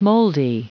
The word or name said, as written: moldy